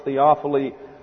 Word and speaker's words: Théophile: That's G-A-U-T-I-E-R. T-H-E-O-P-H-I-L-E is the first name, and it's pronounced Théophile Gautier.